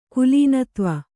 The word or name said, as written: ಕುಲೀನತ್ವ